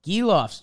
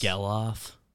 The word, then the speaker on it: Gelof: Because how do you say that? G-E-L-O-F. – Giloff. – Giloff. – Giloff. – Zach Giloff. – And he's a Zach with a K, so I respect it. – Yeah. – I don't respect Zach with ages. – Geloff. – Geloff? That might make Geloff a little more sense. Yeah. Okay. – How do you say it? G-E-H-L.